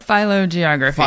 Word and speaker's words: phylogeography: Um, can you uh, can you explain to me what is uh, phylogeography? Did I say that right? – Phylogeography. – Philo. Phy- ah, phylogeography. So close. – Very close.